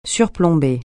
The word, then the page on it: surplomber: French